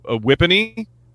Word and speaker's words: Whippany: Ours is Parsippany. Like, Whippany? Wait, what? Yours rhymes with ours?